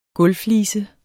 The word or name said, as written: gulvflise